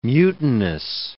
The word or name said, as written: mutinous